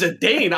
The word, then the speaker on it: Zidane: All right, so Titus, you know, is his name not Zidane? – I don't even want to go into it. That never time. – His name's Zidane. I've never heard it pronounced any other way.